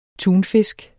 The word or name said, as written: tunfisk